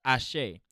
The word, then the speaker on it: Ase: It's spelled A-S-E, but it's pronounced Ashe.